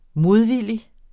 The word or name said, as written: modvillig